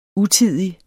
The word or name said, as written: utidig